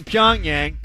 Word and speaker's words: Pyongyang: Tomorrow on the show, Will Graves is in Pyongyang. Am I saying that right? – Sure.